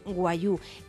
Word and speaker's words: wagyu: Que es como se dice, aunque atención, prácticamente deletreo, W-A-G-Y-U, sería como se escribe, pero se dice guayú.